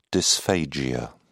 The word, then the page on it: dysphagia: British English